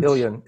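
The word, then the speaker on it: Ilyin: You say that Putin is asking um, people or encouraging people to read three philosophers, Solovyev, um, Berdyaev, and Illyrian, uh, who's I uh, probably mispronounced. – Um, Ilian, Ilian.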